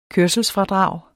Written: kørselsfradrag

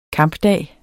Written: kampdag

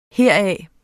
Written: heraf

